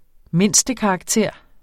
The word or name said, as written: mindstekarakter